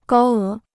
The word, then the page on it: 高额: gāo é